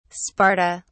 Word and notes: sparta: British English

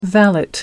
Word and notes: valet: American English

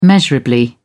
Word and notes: measurably: British English

